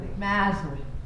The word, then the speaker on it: Masaryk: The future president of Czechoslovakia, who was an acquaintance of Čapek's, Tomáš Masaryk, again, you'll have to forgive my pronunciation. – Mazurek.